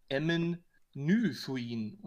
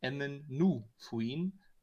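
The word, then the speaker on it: Emyn Nu-Fuin: Ich habe immer gesagt Emmen Nüfuin und eigentlich heißt es aber Emmen Nufuin. Da ist kein I bei dem Nu und deswegen, da habe ich auch einen Fehler gemacht.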